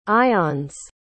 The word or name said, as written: ionnes